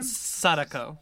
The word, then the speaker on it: Sadako: And Sadako, is that how you say her name? – Sadako.